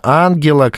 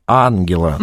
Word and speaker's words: Ангела: Ангела, как и Меркель, ударение на первый слог. Ангела.